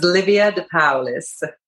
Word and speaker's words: Livia De Paolis: So, my name is Livia DePaolis.